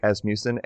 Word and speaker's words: Asmussen: It is Erik Asmussen.